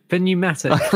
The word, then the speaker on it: pneumatic: Pneumatic. – Is it pneumatic? – Pneumatic, yeah. – The P is silent.